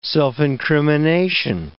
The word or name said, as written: self-incrimination